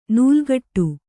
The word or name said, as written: ನಾಲುಕಟ್ಟು